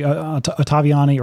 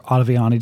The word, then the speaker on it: Ottaviani: the Ottaviani or Ottaviani, depending on how you want to pronounce that, intervention.